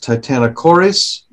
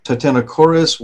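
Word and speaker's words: Titanokorys: It's Titano, like titanium, T-I-T-A-N-O, followed by the two-syllable word chorus, K-O-R-Y-S. So if you're looking it up on a search engine, that's what you would put in. Titanochoris, it was a bewildering animal.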